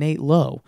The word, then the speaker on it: Lowe: Don't have two guys with the same last name pronounced differently. That's rude. Nate Lowe won AL. I think it's Brandon Lau and Nate Lowe.